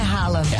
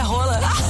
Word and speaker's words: Haaland: Se eu contar pra vocês que a pronúncia do Haaland não é Haaland. – É Hala, Hala, Hala? – Não, é Rola. Eu juro.